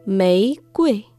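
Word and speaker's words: Mei Kui (玫瑰): Rosa en chino se dice Mei Mei Kui. Mei que Y el primer carácter, Mei, con tono ascendente. Mei, Mei y Kui, tono neutral.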